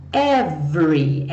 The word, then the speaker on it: every: Então, olha só, every, essa palavra que vai aparecer muitas vezes. O é do meio não é pronunciado. Então, nada de falar every, every, tá? Finge que ele não tá aqui.